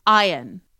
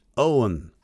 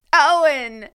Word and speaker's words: Eoin: Okay, next one, E-O-I-N. – Ion. – Ion? – It's pronounced Owen. – Oh. – Owen. – As in the actor, like, Owen Wilson or Clive Owen. Exactly like that.